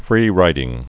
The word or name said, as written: free rider